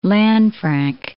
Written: Lanfranc

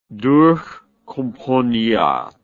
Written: durchkomponiert

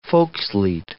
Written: volkslied

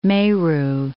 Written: Meru, Mount

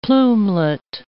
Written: plumelet

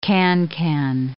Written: cancan

